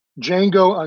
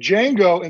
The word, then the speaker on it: Django: And Django in the sequel changes his name to Jim West because he's tired of saying the D is silent.